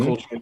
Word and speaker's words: Coltrane: 66, John Coltrane. Did I say that right? – Coltrane.